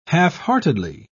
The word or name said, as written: halfheartedly